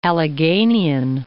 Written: Alleghenian

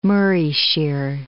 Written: Morayshire

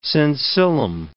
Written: sensillum

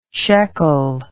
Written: sheqel

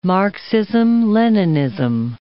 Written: Marxism-Leninism

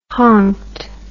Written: honked

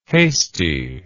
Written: hastie

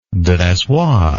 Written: dressoir

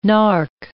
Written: nark

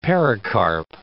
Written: pericarp

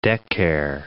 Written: decare

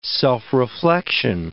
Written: self-reflection

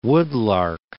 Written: Woodlark

